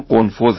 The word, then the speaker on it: قنفذ: اشتباه تلفظ کردم، قنفوز هست با قاف و پایانش هم زی دالزال.